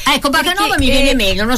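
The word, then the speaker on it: Bakanova: È l'usanza di pronunciare tutti i cognomi slavi, anche quelli della Repubblica Ceca, di Bulgaria, che finiscono con va. Noto questa tendenza in Italia, però originalmente l'accento cade su un'altra sillaba, perciò io mi sono abituata di essere chiamata Bacanova.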